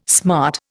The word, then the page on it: smart: Indian